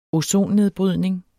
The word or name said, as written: ozonnedbrydning